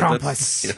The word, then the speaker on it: Krampus: Are we saying it correctly? – Yeah, I mean, the Germans would probably put a little more oomph into some of the vowels there. – But Krampus. – Yes, exactly.